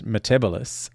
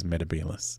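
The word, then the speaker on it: Metebelis: And that when the Matt Smith Doctor calls the planet Metebalus, that's how it's meant to be pronounced, and that it was, in fact, the third Doctor who was mispronouncing it as Metebalus.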